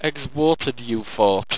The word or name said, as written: egzwateredufort